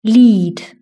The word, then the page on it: lead: British English